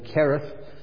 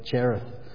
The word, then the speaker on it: Cherith: the Hebrew pronunciation would be more correctly Cherith, but I'm using the English pronunciation. With my Aussie accent, I'll get it wrong whatever I say anyway, so I'll stick with the word Cherith.